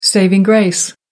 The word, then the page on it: saving grace: American English